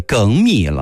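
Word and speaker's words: 粳米: 还有这样一个词儿，好多人每天中午吃的饭可能都是这种米，原本我们把它读成叫精米，但现在改读成为梗米了，不是精米了。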